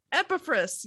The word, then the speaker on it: Epaphras: Epaphras.